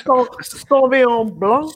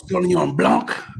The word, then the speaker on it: Sauvignon Blanc: You got to say it like that. Sauvignon Blanc. – Sauvignon Blanc. Yeah.